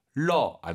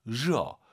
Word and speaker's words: 热: 러 안 돼요. 热. 热 발음 잘하시고요.